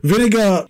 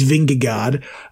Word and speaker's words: Vingegaard: He might still back up and be the leading light for the Tour because Vingegaard is how you pronounce it, even though it's Vingegaard.